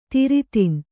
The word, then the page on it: ದೊರೆತನ: doretana